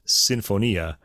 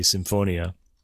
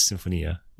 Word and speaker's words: Sinfonia: By the way, the pronunciation of that word in Italian anyway is Sinfonia. Americans often say Sinfonia, but it is Sinfonia.